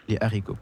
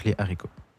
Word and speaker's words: haricots: Les Haricots, parce qu'on ne peut pas prononcer le Z, parce qu'il y a un H. Donc, Les Haricots.